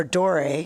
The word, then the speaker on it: Dore: two hundred plus from Aimé Leon Dior or Dore. I've said it wrong, not Dior.